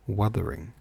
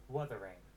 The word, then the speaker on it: Wuthering: And am I saying it right? Wuthering. – Wuthering. – Wuthering. Wuthering Heights. Cool. So I wasn't saying it right, and I had no idea what it was, what it meant.